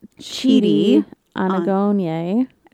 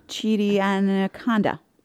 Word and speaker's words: Chidi Anagonye: Eleanor can't say his name. – Chidi Anaconda. Chidi Anagrande. That's a person. I did it. – Chidi Anakendrick. – Chidi Anakendrick, she calls him at one point.